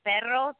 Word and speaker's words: perro: Yeah, it's important, though, in perro to roll your R's, because if you say pedo or pedo, it means fart in Spanish.